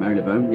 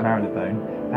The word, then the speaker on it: Marylebone: We're near Marylebone Station, if that's how you pronounce it. – Marylebone, yeah. – Marylebone.